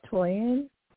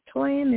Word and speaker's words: Toyin: My name is Toyin. – I spell that for us because the volume is not good. – Oh, okay. My name is Toyin. It's T-O-Y-I-N.